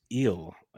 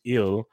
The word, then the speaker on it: Gil: Luis Eel, as the way I heard them pronounce it on the Yankees broadcast. So good. I'm glad you said it first. – Yeah, I would have gone Gill. I had friends' last name Gill my whole life. But the G is silent, it sounds like.